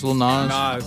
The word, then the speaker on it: Nas: Nas.